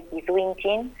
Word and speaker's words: Kiduinchin: Yo estoy hablando desde Bariloche, como dijeron, soy parte de Kiduinchin, se pronuncia de esa manera.